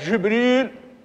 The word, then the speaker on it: جبريل: جاءت جبريل بكسر الجيم والراء، في وهي لغة قريش، لغة الحجاز عفوا، وأفصحها قرأ بها نافع وأبو عمرو وابن عامر وحفص عن عاصم، قرأ بهذه اللفظة جبريل،